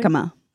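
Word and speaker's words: jícama: Jicama.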